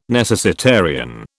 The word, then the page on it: necessitarian: British English